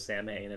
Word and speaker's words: Samhain: Yeah, Samhain, however you fucking pronounce that one. – I think you said it right the first time.